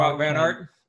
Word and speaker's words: Wout Van Aert: if somebody like a Wout Van Aert, however you say his name, Van Aert. – Wout Van Aert.